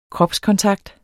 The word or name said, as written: kropskontakt